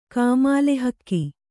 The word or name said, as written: ಕಾಮಾಲೆಹಕ್ಕಿ